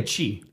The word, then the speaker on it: Chi: It's spelled C H I. I don't know if that's like Yeah, a chi. Chi. Yeah. Okay. – That place is good. All right because it's spelled the same as the Greek letter chi.